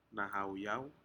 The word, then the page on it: nahauyau: Aneityum